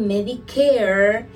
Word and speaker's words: Medicare: Y está el Medicare, terminado en air, siempre, me encanta pronunciarlo, y este es un beneficio federal.